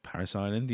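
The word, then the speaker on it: Parris Island: Is it Paris Island or something? – Paris Island, yeah.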